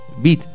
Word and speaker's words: beat: A los 12 años debutó artísticamente en una banda de jazz para cantar algunos temas beat, se dice así, ¿no? ¿Está bien? – Sí.